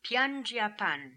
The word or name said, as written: Piandjiapan